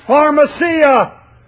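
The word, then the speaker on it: pharmacia (φαρμακεία): The word sorcerer and the word sorcery in the Greek is pronounced pharmacia, from which we get our word pharmacy, pharmaceutical.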